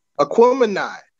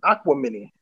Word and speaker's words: Aquemini: Aquemini. Okay, I was about to say Aquamini.